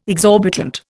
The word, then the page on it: exorbitant: Indian